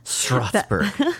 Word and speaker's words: Strasburg: Is there a T in it? – S-T-R-A-S. – Gotta get that T in there. – Yeah. – Strasburg.